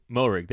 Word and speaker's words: Moehrig: So Trevon Moehrig, however you pronounce it. We'll learn that in the next couple. Moehrig, there you go.